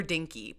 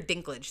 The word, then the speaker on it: Dinklage: And then there's the mobster, Peter Dinky. Peter Dinky, oh my God, Dinky. How dare you? How dare the disrespect? Absolutely not. It's Peter Dinklage. Say it right, bitch.